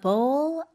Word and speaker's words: bowl: 이 bowl 자체가 그릇을 얘기를 하는데, 이거 발음할 때, 쌤, 그공할때 BALL이랑은 다르죠? – 네, 맞아요. A bowl. 약간 입술을 더 많이 사용해야 돼요.